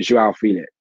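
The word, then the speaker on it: João Félix: I feel like you can't do anything but 4-4-2, and it only works in certain cases. Because when Joao Felix, as you're supposed to pronounce it,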